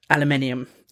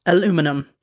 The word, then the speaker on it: aluminium: Americans, I love you and I respect your rich cultural history and all the things that you've given us, but it is pronounced aluminium. It is not pronounced aluminum.